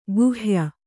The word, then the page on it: ಕೈಹೊಯ್: kai hoy